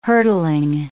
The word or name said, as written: hurdling